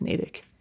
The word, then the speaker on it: Natick: Why isn't it Natick? – Actually, no, I take that back. It's like mile 9, I think, is Natick. – Yes, I don't make the rules. That's just how it's pronounced, unless someone from there wants to write in and correct me.